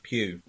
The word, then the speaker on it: Pugh: Pugh. I don't know how to say her last name. – Pugh. – What was it? – Pugh.